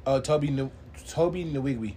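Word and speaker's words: Tobe Nwigwe: I'm going to say his fucking name wrong. – Toby Nwigwe. – There we go. Thank you, because I was going to say his name wrong.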